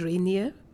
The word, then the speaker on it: Rainier: We went to Mount Rainier.